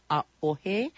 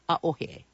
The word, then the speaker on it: A'ohe: A'ohe is spelled A, Okina, and Okina is a glottal stop, O-H-E. So pronounce it A'ohe. There's a break that's brought on by that Okina or glottal stop between the A and the O, A'ohe.